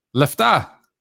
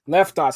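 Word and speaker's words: Lefta (λεφτά): Lefta. – Lefta.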